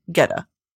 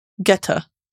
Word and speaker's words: geta: This latter footwear persisted in use into the 20th century as tajita, literally paddy jita, geta. Am I saying it right? From phonetic atiji, using the term. How to pronounce jita? Geta. Sorry, geta.